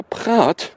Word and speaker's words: Praet: Am Dienstag, nicht müssen, Überraschung bei der EZB, der Belgier Peter Praat, geschrieben Praet, aber ich habe ihn inzwischen ausgesprochen gehört, wird nämlich neuer Chefsvolkswirt der Europäischen Zentralbank.